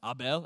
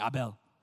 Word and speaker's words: Abel: But for all you Hebrew scholars, I think his name was actually pronounced Abel. Everyone say Abel.